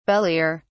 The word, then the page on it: belier: British English